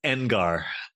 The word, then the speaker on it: Engar: No, it's Engar.